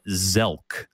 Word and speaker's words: SELK: And in German, the initials are S-E-L-K, and you pronounce it Zelk, because this is how it works in German. Anything that starts with an S, you just pretend it's a Z.